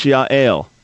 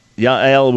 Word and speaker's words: Yael: In Hebrew, that's Yael. It's a nice Hebrew name. One of my closest friends in Israel has a daughter by that name, Yael. So Yael would be how to pronounce it.